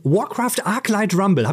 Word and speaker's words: Warcraft Arclight Rumble: Darum geht es auch in Warcraft Arclight Rumble.